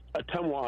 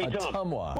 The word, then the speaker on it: Ottumwa: It's pronounced Ottumwa, Iowa. Hey, Ottumwa.